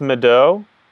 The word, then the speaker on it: Madeaux: So your name is pronounced Madeaux. – Yes.